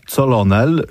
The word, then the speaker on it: colonel: This is not colonel, this is colonel. Colonel. I know, crazy. – This is Colonel Picard. – Colonel. – Colonel. – Colonel. And here's Colonel Stone. – Colonel. – Colonel. – Colonel. – So it's cur, cur, no, no, colonel, colonel. There's no L in the middle.